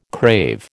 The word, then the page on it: crave: American English